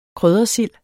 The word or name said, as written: kryddersild